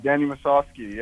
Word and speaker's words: Musovski: I want to pronounce his last name correctly. Danny Musovski? – Yeah, Danny Musovski, yeah.